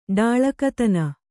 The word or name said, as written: ಡಾಳಕತನ